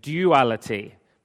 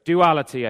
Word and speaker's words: duality: or as I used to say when I lived in England, duality, but I'm told that I've adopted a Canadian pronunciation for some words. So duality, I think, is what we say here in North America.